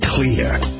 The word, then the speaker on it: Xlear: Introducing Clear. It's pronounced clear, but it's spelled X-L-E-A-R.